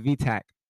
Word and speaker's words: VTEC: No, Joe, you didn't say it right. It's not a VTEC. It's a VTAC.